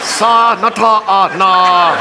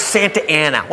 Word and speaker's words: Santa Ana: Sa nata na. How? It's Santa Ana. What says Santa Ana? Look, I know I didn't finish school, but that frickin' says Sa Nata Ana. They pronounce it Santa Ana. Well, they're wrong! – Sa Nata Ana, Sa Nata Ana, Sa Nata. – It's Santa Ana. Are you sure? – Yes, okay!